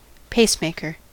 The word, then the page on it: pacemaker: American English